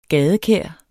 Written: gadekær

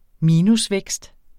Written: minusvækst

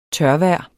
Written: tørvejr

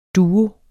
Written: duo

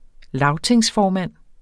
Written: lagtingsformand

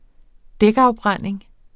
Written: dækafbrænding

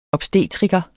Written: OBSTETRIKEREN

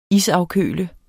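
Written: ISAFKØL

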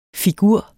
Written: figur

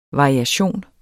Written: variation

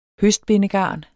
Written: høstbindegarn

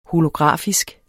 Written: holografisk

